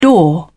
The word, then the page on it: door: British English